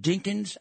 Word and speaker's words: Dinkins: You would have 8 years of no riots when your mayor, Dinkin, I think that's the way you pronounced it, Dinkin. By the way, it's pronounced Dinkins, Al, not Dinkin.